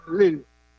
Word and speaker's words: Lille: En una ciudad que se llama, se escribe Lille, se pronuncia Lille.